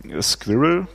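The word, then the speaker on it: SQRL: Squirrel wird es, glaube ich, ausgesprochen, ein Secure Quick Reliable Login.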